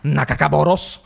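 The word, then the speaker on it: Nagakabouros: Cioè un tentacoloso, che si chiama Nakakaboros. – Perfetto, e proprio così si deve pronunciare, incazzare. Non esiste la versione tranquilla di questo nome.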